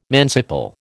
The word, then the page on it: manciple: American English